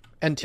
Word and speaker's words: NTSC: And now knowing that you can actually have the, like, wait, NSTC? – NTSC. – Is that how you say it? Yeah. – NTSC, yeah.